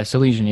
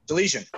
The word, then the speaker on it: Salesian: Guys, and then coming in from this, I hope I'm saying it right. I thought we kept butchering it. The Salesian? – Yeah, Salesian, yeah. – Salesian. Hey, I was close.